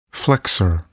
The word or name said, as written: flexor